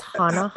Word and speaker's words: Hanahana: It's spelled H-A-N-A-H-A-N-A. – Oh, that's Hanaha. – It's Hanaha, pretty sure.